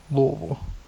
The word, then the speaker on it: Louisville: It's Louisville. It's Louisville. – Louisville. – Gotta say it with the mouth of rocks.